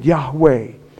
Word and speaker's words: Yahweh: Yahweh, the Hebrew name, tetragrammaton, like four letters in the Hebrew, originally without what they call vowel points, but that's the way it would be pronounced back then.